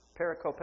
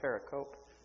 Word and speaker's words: pericope: Those are the discourses that lead up to this pericope, or this idea, pericope. Dick, is that the right pronunciation? It's pericope or pericope. It's a Greek word for a story.